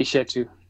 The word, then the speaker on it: Eshetu: It's actually Eshetu.